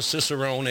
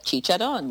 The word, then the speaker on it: Cicerone: Explain what a Cicerone is, and am I saying it correct finally? – You are saying it correctly. You used to call it Chicharron, which I like too.